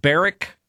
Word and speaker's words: Barack: I thought it was Barack because, I mean, you know, as woke as Columbus is and it's spelled B-A-R-A-C-K. I figured it had to be named after Obama, but apparently it's not. It's Barack. No, it's the Barrack.